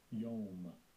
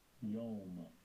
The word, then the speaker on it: Yom: Yom. Yom.